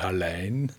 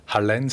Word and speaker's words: Hallein: Also vielleicht Hallein, also muss man auf die Betonung aufpassen, habe ich erst letztens im Radio gehört, Hallein. Viele sagen Hallein, aber Hallein sagt man.